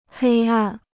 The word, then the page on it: 黑暗: hēi àn